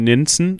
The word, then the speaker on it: Nenzen: Und zwar sind viele äh, Netzen, äh, so spricht man Nenzen, Nenzen Entschuldigung, Nenzen. mit N,